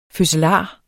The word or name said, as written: fødselar